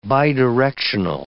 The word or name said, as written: bidirectional